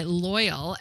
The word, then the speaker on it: Loial: And so this woman's name is Rita, R-E-E-T-A. And I think her last name is pronounced Loy, L-O-I, and she goes by Loyal, L-O-I-A-L.